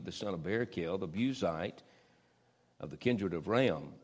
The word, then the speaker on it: Buzite: Then was kindled the wrath of Elihu, the son of Barakel, the Buzite. You remember we were introduced in the book of Job. I picked it, Chris Wilbur. I pronounced this word Buzite. It's actually pronounced Buzite.